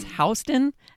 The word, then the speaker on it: Houston: Because like in New York City, there's a street that's spelled Houston, mm-hmm. but it's Houston. And so the first time I said, oh yeah, it's like south of Houston Street, everyone was like, okay, yeah, you actually live here, Samer.